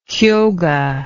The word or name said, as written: Kyoga